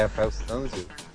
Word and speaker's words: Rafael Sanzio: Leonardo da Vinci, Rafael, Rafael Sanzio, é essa a pronúncia correta? – é, Rafael Sanzio.